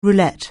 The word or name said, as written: roulette